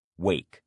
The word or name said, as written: wake